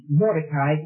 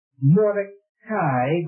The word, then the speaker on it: Mordecai: Now these names, Mordecai, if you look at it in the Hebrew, it's Mordecai, but then the English pronunciation, they tell you to give it Mordecai, and so I'm liable to call it Mordecai or Mordecai, and Hegai or Hegai, or you may take your choice.